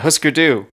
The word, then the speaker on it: Hüsker Dü: Do you know how to pronounce this next one? – Yeah, Husker Du. – Is that it? Is that easy? – That's how I've always heard it said.